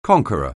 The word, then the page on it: conqueror: anglais